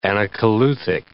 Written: anacoluthic

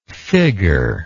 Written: figger

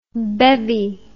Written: bevvied

